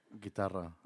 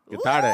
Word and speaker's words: guitarra: Guitarra. – Guitarra.